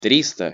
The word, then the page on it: trista: Russian